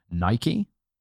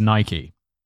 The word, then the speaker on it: Nike: And everyone has a go at me being like, it's Nike. And I'm like, yeah, I know. But look, if I pronounce it Nike, every single British person watching these videos is going to be like, stop pandering to the American. Yeah. We pronounce it Nike.